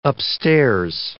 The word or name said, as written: upstairs